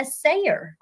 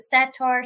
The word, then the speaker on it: satyr: But I do believe what the goat man is and what people see and have reported the most is a sayer. A lot of people may pronounce it satyr, sayer.